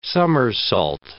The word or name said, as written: somersault